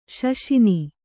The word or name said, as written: ಶಶಿನಿ